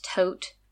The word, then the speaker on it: Thoth: If you're not super familiar with the history of ancient Egypt, the god Thoth, also sometimes pronounced Tot or Thoth,